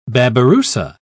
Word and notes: babirussa: British English

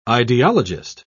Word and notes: ideologist: British English

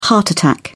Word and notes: heart attack: British English

